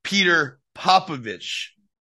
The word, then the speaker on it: Petar Popovic: And that's Peter Popovic. – Yes, good pronunciation.